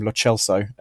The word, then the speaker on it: Lo Celso: we should be calling him Lo Celso, as in the Argentinian pronunciation, or Rodrigo Bentancur.